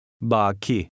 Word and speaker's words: Baki: Now listen and repeat number 12, which has a long A. – Baki.